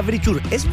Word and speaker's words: Verisure: Claro, muchachos, no diga Verisur, es Verisur, así como se escribe.